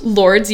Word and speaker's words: Lourdes: It's called Lourdes? – Lourdes, yeah. And like, how do you spell it? – L-O-U-R-D-E-S.